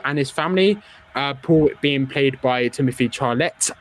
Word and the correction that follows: Chalamet: I'm not sure about the second name pronunciation. – Timothy Chalamet. – Yeah. There we go. I absolutely butchered the surname there.